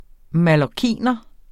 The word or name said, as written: mallorciner